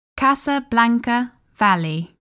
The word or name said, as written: Casablanca Valley